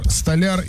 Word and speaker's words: столяр: Как правильно говорить? Столяр, столяр или допустим оба варианта? – Оба. – Столяр, правильно говорить.